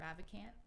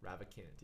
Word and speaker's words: Ravikant: Uh, Ravikant. – Ravikant, yeah, that book was unreal.